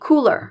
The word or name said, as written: cooler